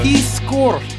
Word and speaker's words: Piskor: This guy, Ed Piskor. I think that might be how you pronounce his name. But Piskor. – P-I-S-K-O-R.